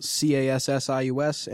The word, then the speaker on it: Cassius: That's spelled CASSIUS.